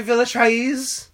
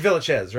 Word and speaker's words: Villechaize: Hervé Villechaize? – Is that how you pronounce it? – I don't think so, but it is fun to say like that. It's just Villechaize, right? – Sure. – But I think it's spelled A-E-Z, it's fun to...